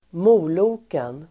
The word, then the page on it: moloken: [²m'o:lo:ken]